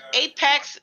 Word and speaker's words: Apex: Could Apex, because I was saying Aspex for like 2 weeks, so I finally said it right.